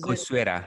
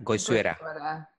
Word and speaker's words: Goizueta: And also with our design team here, she's recently launched products including Georgia Tech's Workforce of the Future and Emory University's, I can't pronounce this word. What is Goizueta. – It? – Goizueta.